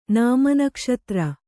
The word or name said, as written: ನಾಮನಕ್ಷತ್ರ